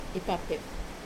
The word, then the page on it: pape: wamesa